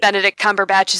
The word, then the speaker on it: Benedict Cumberbatch: Benedict Cumberbatch is in there somewhere, as with every conspiracy.